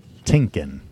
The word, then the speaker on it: Tienken: Tienken, like thinking.